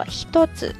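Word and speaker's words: ひとつ: 与之相对应的还有另外一个读法，我们说训读 kun y o m i d i s 呢，读作 hitozu。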